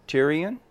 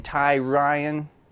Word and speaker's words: Tyrion: Tyrion? – Tyrion? – Tyrion. – Okay. Tyrion? – What, were you like being Ty Ryan? – Yeah, there's y'all yeah, pronounce it some different way.